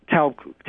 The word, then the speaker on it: Taub: Tolb treatment at the Tolb Clinic, Taub Clinic, I mispronounced that.